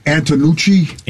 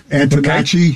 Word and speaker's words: Antonacci: I like Antonucci. – Antonacci.